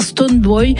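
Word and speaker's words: Stonebwoy: On va écouter la révélation Melissa et le patron de l'Afro-Dance Hall ghanéen Stoneboy sur le titre Show Me. – Absolument, et bravo pour la prononciation de Stoneboy.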